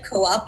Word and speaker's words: Coop: It's spelled like co-op, but yes, we have to be different at Harvard. We call it Coop.